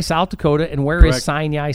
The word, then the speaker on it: Sinai: S-I-N-A-I. – And how do you pronounce it? – Sinai. – Really? – Yeah, I guess so. That's got a weird emphasis on a weird syllable. I would say that would be Sinai. – Yeah, yeah, like out of the Bible, correct.